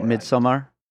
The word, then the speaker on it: Midsommar: Is it Midsommar or Midsommar? – Midsommar. Yeah.